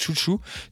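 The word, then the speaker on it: Xiu Xiu: Et euh, j'enchaîne sur euh, Chouchou, qu'on prononce, euh, donc en fait c'est Xuxu, mais qu'on prononce Chouchou.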